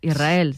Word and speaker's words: Israel: Y de diminutivo, de chiquitito, Israel, que ella quería... Porque Israel creo que le sonaba muy grande, ¿no? Israel, ¿no? – Y la S con la R es difícil de pronunciar.